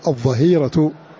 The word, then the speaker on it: الظهيرة: ثم قوله كذلك في هذه المنظومة الظهير, الظهيرة شدة الحر, ومنه قوله تعالى وحين تضعون ثيابكم من الظهيرة, فالظهيرة بالضاء ولا تنطق بالضاد, وحين تضعون ثيابكم من الظاء من الظاء تقع بالضاء لا تنطق بالضاد, وحين تضعون ثيابكم من الظهيرة في سورة النور آية 58.